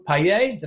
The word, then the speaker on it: Payet: Now in her third, she's expanding her online activities to include a collaboration with fellow funnel hackers Mario Payet, did I say that correctly? – You did, Payet, yep. – Payet and Callum Ewing called Tribe Tools.